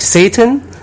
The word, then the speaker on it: Zayden: And Zayden would be pronounced something more similar to like Satan.